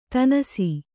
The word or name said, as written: ದಿನಸಿ